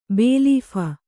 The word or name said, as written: ಬೇಲೀಫ